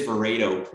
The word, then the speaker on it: Verato: Sure. It's Verato. – Oh, I apologize, Verato. – No worries at all. No worries at all. Everybody, it's pronounced every, you know, for every human, there's a different pronunciation of it, but it is Verato.